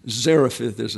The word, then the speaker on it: Zarephath: Zarephath is the pronunciation.